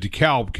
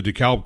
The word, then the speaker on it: DeKalb: And if you go up into the north, like up here in Indiana, you'll see the DeKalb corn signs everywhere because the corn company does pronounce the L. It is not DeKalb corn, it's DeKalb corn.